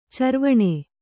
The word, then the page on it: ಚರ್ವಣ: carvaṇa